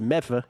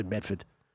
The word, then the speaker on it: Medford: There's also Medford, pronounced Medfa, he says. It's really Medford, much like Worcester.